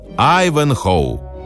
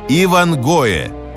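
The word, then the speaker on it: Ivanhoe: На английском так и произносится «Айвенхоу». И что сделали русские горе-переводчики, когда собирались выпустить книгу в нашей стране? Они прописали это самое слово «Айвенхоу» буквами кириллицы. И получилось «Ивангое». Так и напечатали.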